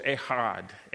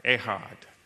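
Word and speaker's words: ehad (אחד): When I looked up the Hebrew and looked up the definition of one man, that Hebrew word was ehad, ehad.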